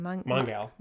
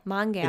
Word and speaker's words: Mongal: Mongal. – Mongal. – It's supposed to be like Mongol, but she's a girl.